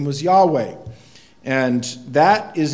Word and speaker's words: Yahweh: And you'll see it in your English Bible, capital L, small O-R-D. And then there's the other term, Yahweh. And Yahweh or Jehovah, depends on how you want to pronounce it, but Yahweh is probably the way it should be pronounced.